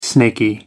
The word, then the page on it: snaky: American English